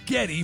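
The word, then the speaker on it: Geddy: Gary would become known as Getty, playing on Gary's mom's heavily accented pronunciation of his name. Getty! Getty! After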